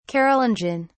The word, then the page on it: carolingian: British English